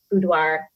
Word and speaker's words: boudoir: It sounds just like every facet that you work with kind of does that, especially boudoir. And I say that word weird. Am I saying it right? – Boudoir.